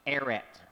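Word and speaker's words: Eret (ארץ): There is a Hebrew word that appears over and over in this section of Scripture, and it is pronounced Eret, mas o menos.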